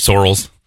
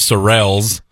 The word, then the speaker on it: Sorrells: So, my last name is actually Sorrells. – Okay. – That's the proper pronunciation.